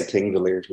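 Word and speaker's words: Þingvellir: The other day, Thingavallen, is that how do you pronounce it? – I'll try my best. So most people say Thingvellir, like Thingvellir.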